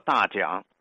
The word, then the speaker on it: 大港: 当地人把大港读作大讲。